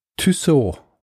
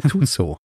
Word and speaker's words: Tussaud: Ein Kritikpunkt, der öfter kam, ist, man könnte auch, oder eigentlich müsste man Tussaud sagen natürlich. Tussaud ist ja die anglisierte Version.